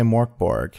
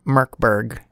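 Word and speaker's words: Mörk Borg: I know you want to say Morkborg. I want to say Morkborg every time. I want to say it, but apparently it's pronounced something closer to Merkberg.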